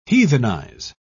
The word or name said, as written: heathenize